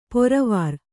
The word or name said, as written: ಪೊರವಾರ್